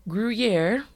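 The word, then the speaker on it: Gruyère: But the main three that they supported were Emmental, Gruyere.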